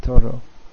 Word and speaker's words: Thoreau: Thoreau, is that how it's pronounced? Probably in French, it was French name, right? It's pronounced Thoreau.